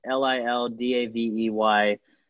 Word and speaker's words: LilDaveyFritos: It's Lil Davey Freitas, LilDaveyFritos. FRITOS, like the chips, and spelled like a rapper for some reason.